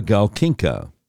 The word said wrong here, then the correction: Galkina: What did, I'm sorry, did I mispronounce her name? Olga Galkina.